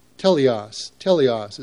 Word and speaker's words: teleios: The Greek word that's translated mature here is teleios. It's spelled T-E-L-E-I-O-S.